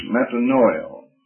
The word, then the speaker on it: metanoia: A, the word in the Greek language is metanoia. Metanoia. M-E-T-A-N-O-E-O, the last O is a long O, an omega.